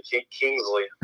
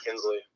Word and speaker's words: Kinsley: Most people just say Kingsley instead of Kinsley. – Yeah, my name's spelled T-A-I, so I'm familiar with wrong pronunciations.